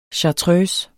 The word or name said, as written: chartreuse